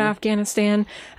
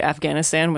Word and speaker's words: Afghanistan: So even the country, it's a very Americanized way to say it, Afghanistan, with that hard G. I've tried to pronounce it Afghanistan, It's closer to something like that. Afghanistan.